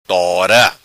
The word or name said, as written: Tora